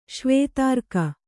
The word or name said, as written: ಶ್ವೇತಾರ್ಕ